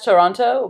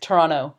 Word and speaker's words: Toronto: Oh, like, they don't pronounce the second T in there. – How? – Come so on. It's like, you read it as Toronto, but people from Toronto, they say like Toronto.